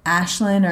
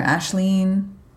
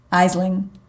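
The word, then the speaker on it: Aisling: I believe the Gaelic pronunciation is Ashlyn or Ashleen, but it looks like Isling or Islin.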